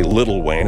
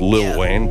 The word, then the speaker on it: Lil Wayne: I say Little Wayne. It's Lil Wayne, but I know only you have to pronounce that properly.